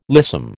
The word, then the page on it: lissom: American English